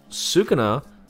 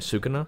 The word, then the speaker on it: Sukuna: So now we're stuck at a time where Sukuna, that's how you pronounce it, right? Sukuna? – Yeah.